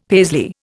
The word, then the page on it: Paisley: Indian